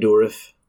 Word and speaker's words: Dourif: Dourif, I'm not sure if I'm saying it right. – Yeah, Brad Dourif.